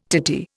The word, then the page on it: titty: Indian